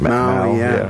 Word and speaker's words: Mao: How do you pronounce it? – Mao. Yes.